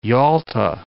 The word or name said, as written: Yalta